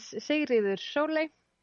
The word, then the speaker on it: Sigríður Sóley: My name is Sigríður Sóley.